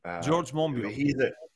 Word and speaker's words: Monbiot: And the reason that this kind of smear, I think, has some purchase, especially amongst sort of leftish types, who George Moinbot, how do you say his name? – George Monbiot.